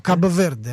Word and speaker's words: Кабо-Верде: Кабо-Верде.